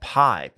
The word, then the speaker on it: Pii: A TikTok person, a noted TikTok personality, I believe her name is Chef Pi, P-I-I. – Says, pronounced P. – Chef P, should have known.